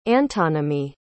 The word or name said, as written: antonymy